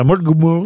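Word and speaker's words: Mullaghmore: Une bombe télécommandée de 23 kilos a été placée près du moteur de l'embarcation dans une baie irlandaise, à Mulgmore. Je sais pas si je prononce bien. – Non, c'est très bien prononcé.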